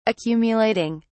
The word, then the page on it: accumulating: British English